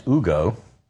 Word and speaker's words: Hugo: which is apparently run by a guy named H-U-G-O, which I'm pretty sure is pronounced Ugo, not Hugo, but around these parts it's Hugo.